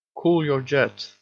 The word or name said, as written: cool your jets